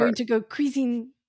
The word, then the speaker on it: cuisine: But that's how you say it. Cuisine.